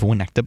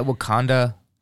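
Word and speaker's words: Winnetka: Yeah, we get Wakanda Bowling League.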